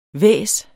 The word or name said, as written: hvæs